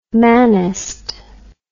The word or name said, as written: manist